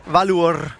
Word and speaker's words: Valur: Hvordan er det med, du udtaler det? – Valur. – Valur fra Reykjavik. Det er nemlig rigtigt.